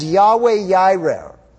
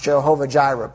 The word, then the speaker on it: Yahweh Yireh: And Yahweh Yireh, or it's often pronounced Jehovah Jireh, but Yahweh Yireh is the way it would be said in Hebrew.